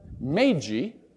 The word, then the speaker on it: Magi: They were Magi. That's the correct pronunciation of Magi.